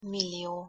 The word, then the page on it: millio: Hungarian